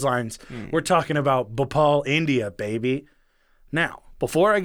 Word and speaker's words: Bhopal: So, Bhopal.